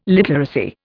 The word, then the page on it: literacy: Indian